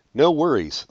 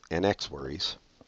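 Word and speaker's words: NxWorries: My next song is No Worries. That's how it's pronounced, even though it's NX Worries.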